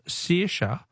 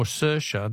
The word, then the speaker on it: Saoirse: Ellen from Footscray says, the Irish name Saoirse is pronounced either Saoirse or Saoirse, depending on which county you come from. Oh, gee, that's a level of detail, Helen, that I can't cope with. I'm just going to stay with Saoirse. It's the best I can manage for a name that is spelt S-A-O-I-R-S-E.